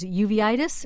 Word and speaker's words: uveitis: So in this case, Watson determined that there was a 91% chance it was uveitis. Am I saying that right? – That's correct.